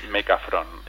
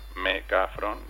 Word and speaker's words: Mecafrón: Se llama Mecafrón. – Mecafrón. – Mecafrón. Es la... Mecafrón, que es la primera sílaba de me cago en la frontera.